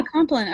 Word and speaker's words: Compline: Compline? Yeah, Compline.